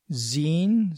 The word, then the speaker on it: Xine: Some of the others, SM Player, Miro, MPV Player, GNOME Videos, Dragon Player, Zine, spelled with an X, X-I-N-E, Multiplayer Engine, and Deepin Movie.